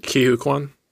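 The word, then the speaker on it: Ke Huy Quan: And in the trailer, and I don't want to say his name wrong. – Ki-Hu Kwan? – Yes, thank you.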